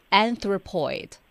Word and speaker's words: anthropoid: 好，我们来看这第一个在 anthrop 后面加上了一个 poid P-O-I-D 它的读音呢是 anthropoid 那这个单词啥意思呢？